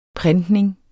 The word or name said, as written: printning